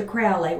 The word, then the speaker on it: Crowley: He referred to Crowley with a long O like the bird.